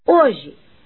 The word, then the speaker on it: hoje: Hoje tem quatro letras e três fonemas, pois vocês não ouvem o som do H, porque não poderiam mesmo. Ele não é pronunciado.